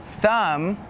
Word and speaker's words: thumb: And some examples with a silent B are lamb, thumb, and doubt.